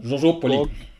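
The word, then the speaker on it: Georgioupoli: Ja, ich habe heute Morgen habe ich deinen Blogbeitrag gelesen über Tavernen und mehr in Georgipuli. Wie wird es ausgesprochen? – Georgopoli.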